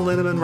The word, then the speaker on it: Linneman: Val Linneman, Rhymes with Cinnamon,